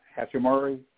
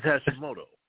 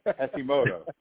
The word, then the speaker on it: Hashimoto: So to everybody's point, to watch D'Angelo and some of the others, and not to mention, you know, how do you pronounce the Hawaiian Hachimori? You know, have a big game. Everybody stepped up. – Hold on. – What we got? – I think it's Hashimoto. – Hashimoto. That's the Japanese pronunciation. I believe you to be correct.